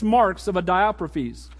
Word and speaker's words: Diotrephes: We'll get to the 6 marks of a how do you pronounce his name? Somebody tell me. Diotrephes? Diotrephes, okay. So make sure.